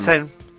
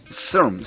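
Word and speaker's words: czerń: ¿Cómo se dice negro en polaco? – Vamos. Xern. – Ah, Xern. – Con Z, Xern. – Xern. – Xern. Y con acento en la N.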